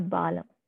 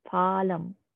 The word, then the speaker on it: Phalam: It's actually pronounced as Phalam, not Balam, Phalam, P-H-A, Phalam.